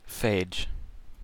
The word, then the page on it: phage: American English